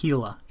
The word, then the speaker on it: Gila: Let's talk Gila monsters. Or Gila. – My friend, it's pronounced Gila. Gila monster.